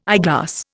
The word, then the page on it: eyeglass: Indian